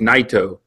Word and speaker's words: Naito: or I said Tetsuya Naito, because that's how I pronounce it, and then the more I listen to the opening ring announcing, I realized it was just Naito.